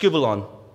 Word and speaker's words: skubalon: This word here is pronounced skubalon. Skubalon.